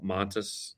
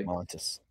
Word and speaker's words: Montes: Cause that was the big, question about like Narcisse as well as Mantis, or excuse me, we've been saying that wrong. Overseas it's called Montes, by the way. – Montes.